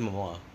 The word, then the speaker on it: Momoa: Jason Moana? – I said Momoa.